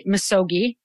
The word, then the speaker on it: misogi: Okay, so you talk about the concept, I think I'm remembering to say it right, misogi.